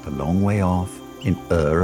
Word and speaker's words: Ur: It started in a place called Ur, which rhymes with fur, ruled by a people called the Chaldeans, which rhymes with Schmaldeans.